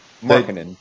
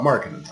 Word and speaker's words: Markkanen: How do you pronounce his last name? I'm horrible. Lauri Markkanen. – Markkanen.